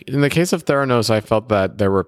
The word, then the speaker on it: Theranos: Like you've made a couple statements about startups, like Theranos. Did I pronounce that correctly? – Yes.